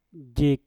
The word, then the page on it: jik: Mpur